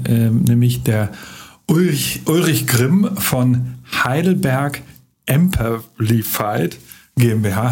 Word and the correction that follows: Amperfied: Habe ich es richtig ausgesprochen, Ulrich? Nein. Ähm, schade. Emperified. – Emperified. – Ganz einfach Emperified.